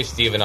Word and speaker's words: Steven: Is it Steve or Stephen? – You know, you can call me whatever you want. – Okay. – If you call me Stephen, I'll appreciate the extra syllable.